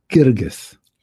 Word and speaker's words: Guirguis: Pronounce it Guirguis. – Guirguis. – Yes. – Okay. – It's different than spelling, actually. – The spelling is really different, too.